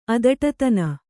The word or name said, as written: ಅದಟತನ